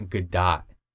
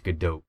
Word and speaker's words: Gadot: it's like you pronounce the T, but it's not Gadot. It's Gadot, because she's Right.